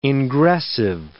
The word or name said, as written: ingressive